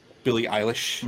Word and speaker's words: Billie Eilish: Your cover of Billie Eilish.